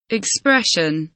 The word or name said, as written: expression